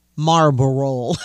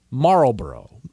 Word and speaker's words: Marlboro: When people do it, when people say it, they usually don't say the L in there, so it's Marlboro.